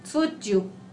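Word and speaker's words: Tzuyu: Este nombre no sé cómo pronunciarlo, pero según es así, Suju de Twice. Suju, ¿no? Porque empieza con T.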